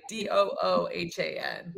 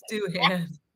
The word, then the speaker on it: Doohan: Is that how it's Jack Doohan? – Yeah. Okay. Doohan, I think it is. – Yeah. See, again, I don't. – It's yeah. Two O's, right? Doohan. – Doohan. – It's Doohan.